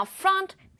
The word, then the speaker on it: front: Now, front sounds like the following words. Hunt, blunt, and stunt.